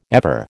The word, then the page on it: ever: American English